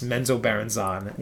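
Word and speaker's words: Menzoberranzan: And that's how it's pronounced? – That's how I watched Bob Salvatore in a YouTube video, and it's Menzo Baranzan.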